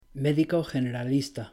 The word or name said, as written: médico generalista